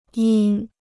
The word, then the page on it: 因: yīn